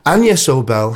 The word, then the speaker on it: Agnes Obel: Agnes, pronounced Agnes, I beg your pardon, Agnes Obel has a new album out called Aventin.